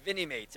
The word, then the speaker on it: Vinimates: You've got some new licenses here with the Vinyl Mate line. – Yeah, Vinimates, actually. It rhymes with Minimates because we use that same sort of block figure format and translate it into a 4-inch dynamically posed figure.